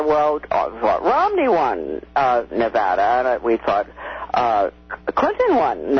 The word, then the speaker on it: Nevada: So I just would I'd like to point out that with the two parties in Nevada, I finally learned how to pronounce Nevada. I used to say, I'm an Easterner, I used to call it Nevada. Nevada.